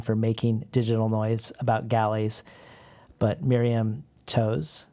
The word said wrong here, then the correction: Toews: It's pronounced Taves, I just learned. – Taves? – Yeah. – Miriam Taves.